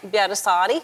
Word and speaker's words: Pietarsaari: Pietarsaari.